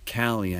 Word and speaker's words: Callie: We're going to give Callie, I think is how we pronounce her name, right? – Yeah.